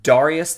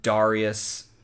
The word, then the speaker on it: Darius: After some discussion, debate, and even a possible apocryphal story of seeing whose horse would neigh first, not even joking, really, Darius I would ascend to the throne and become the second and last Achaemenid to have the moniker The Great after his name. – Mm. – Or, you know, Darius, that's like the more Latin pronunciation, or it's more like Darius or Darius.